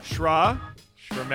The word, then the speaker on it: Sramaty: Shramatty.